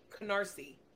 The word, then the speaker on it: Canarsie: No, Carnassie. And I'm saying that wrong. Canarsie. That's how you say it.